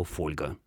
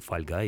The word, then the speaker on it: фольга: например, слово «фольга». Так не говорили уже тогда, когда словарь вышел, но люди должны были говорить, дикторы должны были говорить «фольга», потому что в этом словаре именно так зарегистрировано ударение в этом слове, хотя, конечно, мы все говорим «фольга», и только так это уже на протяжении...